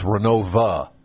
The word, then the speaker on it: Renovo: Now, the folks in town say it's Renova. – They do. – Not Renovo. If you say Renovo, that means you're an out-of-towner.